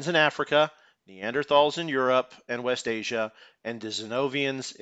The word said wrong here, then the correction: Denisovans: I'm probably not saying that right. Denisovians in Asia. – Denisovans. – So, Denisovans. Yeah.